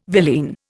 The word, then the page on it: villein: Indian